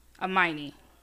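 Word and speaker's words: Aminé: I believe his name is pronounced Amine.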